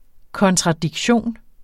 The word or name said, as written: kontradiktion